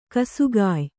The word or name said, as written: ಗಾಸಿಗೆಯ್